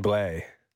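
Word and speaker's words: Blais: And 50% retained from the Blues for Sammy Blaze. – Sammy Blay.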